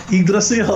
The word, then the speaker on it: Yggdrasil: I actually looked up the pronunciation. – Mm-hmm. – Oh, Frank Carroll's right. Yggdrasil.